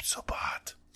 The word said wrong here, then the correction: Noob Saibot: I know, I said it wrong, but Noob Saibot.